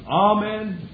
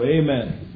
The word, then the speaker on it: Amen: saying, Amen, or Amen, whatever sounds better on your ear. The pronunciation given in my Bible, if you have a 1901 edition, it puts a long A, Amen. If you're a little more dignified, you'll say Amen.